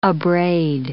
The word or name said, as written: abrade